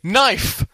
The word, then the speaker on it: knife: I was like, K, like first one, K, as in, quick mark, think of a word beginning with K, quickly, knife! Ugh, you've picked one of the, you know, 29 words in the English dictionary that has a silent K at the beginning.